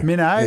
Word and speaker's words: Minto: Was it Minto? Is it Hinto? Minto? – Minnow, mate. Minnow. – Minnow? – That's how they pronounce it.